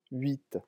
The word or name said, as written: huit